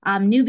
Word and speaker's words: Nyoo: which is an auspicious day for new beginnings in my religion and culture. And New, which is spelled N-Y-O-O, is the phonetic spelling of the word new, which I chose for two reasons.